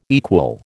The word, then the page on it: equal: American English